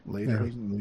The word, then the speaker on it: Lederhosen: Is that how you pronounce that? Lederhosen? – Lederhosen, yeah. Lederhosen, yeah.